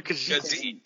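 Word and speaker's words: Khajiit: Wait, did you just fucking call him Kajat? – That's how you pronounce, right? – They're Kajit. Kajit has wares if you have coins.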